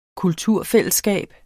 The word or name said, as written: kulturfællesskab